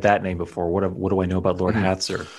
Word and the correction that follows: Hastur: And I'm sorry, I misspoke in the heat of battle. It's Hastur, H-A-S-T-U-R.